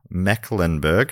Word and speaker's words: Mecklenburg: Don't worry, Matt, it's pronounced how it's spelled. Mecklenburg. Ah, nailed it.